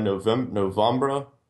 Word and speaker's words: Novembra: Somebody showed me the band Novembra. It's spelled November, but you get your E and R backwards.